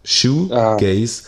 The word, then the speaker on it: Shoegaze: Shoegaze. – Ah. S-H-O-E.